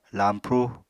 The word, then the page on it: lañpru: apatani